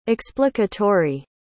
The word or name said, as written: explicatory